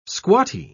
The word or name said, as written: squatty